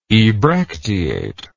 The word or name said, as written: ebracteate